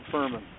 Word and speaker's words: Fuhrman: I'm Bob Furman. My last name is spelled F-U-H-R-M-A-N.